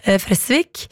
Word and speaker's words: Fresvik: For vi var jo innom denne nydelige bygda i stad, hvor festivalen Fres blir arrangert, og jeg sa Fresvik, så kommer Oddvar på ballen her, og påpeker at det skal jo uttales med kort E, dobbelt S, Fresvik.